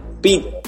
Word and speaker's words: Pett: Pete, ¿quién te nombró Pete Jess Vélez? Mira, Pete como tal eh, se escribe con doble T, pero se menciona Pete, está mal escrito.